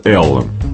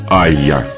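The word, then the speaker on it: Allá: Если читать по-английски, получается Ella. Но сайт лейбла Crime Disc утверждает, что это испанское название нужно произносить как Aya.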